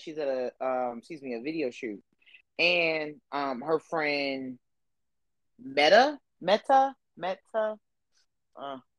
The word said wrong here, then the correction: Mina: Is there and it's Mina. – Mina. – Like Zena, Mina.